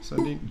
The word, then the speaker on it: Sundin: Sundin? – Yes!